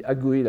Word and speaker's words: Agui: Et Agui, faites attention à la prononciation, ça se lit Agui la plupart du temps, c'est une branche très célèbre de l'école Tendai qui s'est consacrée à la prédication populaire.